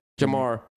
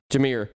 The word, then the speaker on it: Jahmyr: Oh, I said his name wrong again. What is it? Jamar. – Jahmyr.